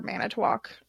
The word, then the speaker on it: Manitowoc: I mean, it's M-A-N-I-T-O-W-O-C, Manitowoc.